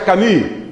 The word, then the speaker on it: Camus: Un señor que se escribe Albert Camus, pero se pronuncia Camus.